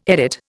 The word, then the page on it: edit: Indian